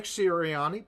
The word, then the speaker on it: Sirianni: Nick Sirianni, brand new coach coming in, obviously.